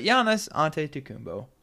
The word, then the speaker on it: Giannis Antetokounmpo: But Giannis Antetokounmpo, very, very good player.